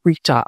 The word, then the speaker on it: Rita: They're still out here available to you, as well as the video versions on my YouTube, which is at Rita Reimers. My name, too, is spelled R-E-I-M-E-R-S. Rita, R-I-T-A, Reimers, R-E-I-M-E-R-S.